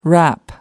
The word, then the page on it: rap: American English